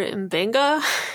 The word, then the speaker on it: Mbenga: So in sickbay, we welcome the cheerful Dr. Mbenga.